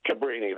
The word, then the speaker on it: Cabrini: Am I pronouncing it right? Cabrini? – Cabrini, that's right, Cabrini, yeah.